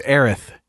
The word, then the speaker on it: Aerith: Sounds like I'm saying Aerith with a lisp, but that's how they say it. Aerith, I believe.